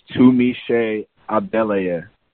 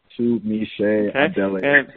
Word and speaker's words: Tunmise Adeleye: My name is pronounced Tunmise Adeleye. Tunmise Adeleye. It's a little bit foreign. It's Nigerian, so it might take people a little bit of time to learn.